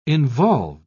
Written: involved

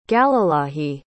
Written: galilahi

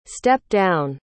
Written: step-down

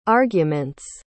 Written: arguments

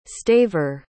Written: staver

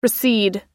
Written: recede

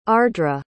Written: ardra

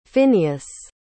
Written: Finneas

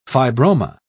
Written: fibroma

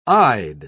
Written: eyed

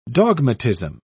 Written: dogmatism

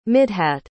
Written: midhat

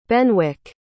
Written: Benwick

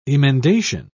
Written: emendation

